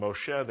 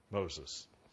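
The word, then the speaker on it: Moshe (משה): The Hebrew text calls him Moshe. The English text calls him Moses, naming him Moshe because I drew him out of the water.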